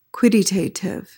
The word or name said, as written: quidditative